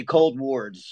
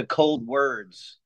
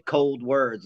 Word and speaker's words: Coldwards: but the pronunciation of the name from it was originally Cold Wards. So you're right on that, like hospital wards. But we decided to change the pronunciation to Cold Words, like a direction towards the cold kind of thing. And also a wordplay, Cold Words, like those are some cold words.